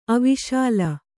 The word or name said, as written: ಅವಿಶಾಲ